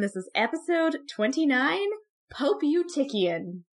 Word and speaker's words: Eutychian: I looked up as much as I possibly could, so I, this is the conclusion I have come to. It's Eutychian.